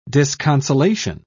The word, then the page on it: disconsolation: British English